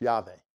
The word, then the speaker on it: Yahweh: Lord, when it's in the caps like that, it's the tetragrammaton, the name that I pronounce, Yahweh.